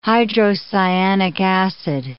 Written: hydrocyanic acid

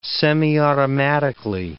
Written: semiautomatically

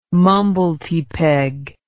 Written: mumblety peg

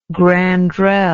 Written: grandrelle

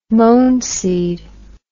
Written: mohn seed

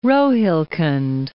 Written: Rohilkhand

